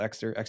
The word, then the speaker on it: Ekster: I think it's a really solid thing for me to be using, but yeah, that's called Exter wallet. Oh, it's spelled E-K-S-T-E-R.